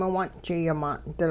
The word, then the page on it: miwung'ji-yimaan'dil: Hupa